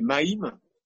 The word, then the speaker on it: Mem: Et Mem, ça se prononce aussi Maïm. Hein, il y a le Ma et le Mi, il y a les deux sonorités.